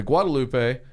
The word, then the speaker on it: Guadalupe: If you take the Guadalupe, if you use the proper pronunciation, it can be rocky at times.